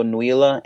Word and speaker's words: Nuila: On me, uh, I'm on Twitter at Rico Nuila N U I L A.